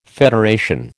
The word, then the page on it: federation: American English